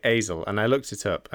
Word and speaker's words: Aizle: It's spelled A-I-Z-L-E. – Yeah, I think it's I think it's Azel. – Azel. Yeah. Azel.